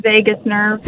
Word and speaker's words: vagus: As hilarious as it would be to... for it to be pronunciated vagus, considering the O-gasm or eargasm, it's actually pronounced vagus nerve.